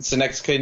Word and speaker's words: Synecdoche: Like one of them is called, if I pronounce it correctly, Synecdoche, New York. I did pronounce it right, okay.